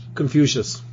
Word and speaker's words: Confucius: Confucius. – Yeah, he had a hand in editing the annals. – Yeah. – He has a hand in everything. – Yeah, Confucius say.